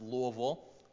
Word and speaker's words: Louisville: So for example, whenever I moved to Louisville, Kentucky, and it's pronounced Louisville, if you've ever been there, moved to Louisville, Kentucky for seminary, I became a University of Louisville fan, U of L.